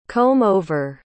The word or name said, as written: comb-over